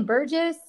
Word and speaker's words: Burgess: Now, I mentioned that I stumbled across you on Twitter, and then I think Dave, how do you say that last name? Burgess? – Yeah, you got it.